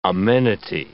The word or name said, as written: amenity